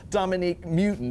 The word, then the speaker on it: Dominique Mewton: And at the bottom right down there, that's Dominique Mewton.